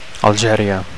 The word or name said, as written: Algeria